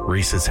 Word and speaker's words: Rhys: How do you pronounce R-H-Y-S? – Reese. – Reese? – Oh, Reese, yeah.